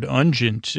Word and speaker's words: unguent: And it is a beautiful word, ungent, uh, rhymes with pungent, in case you're rapping.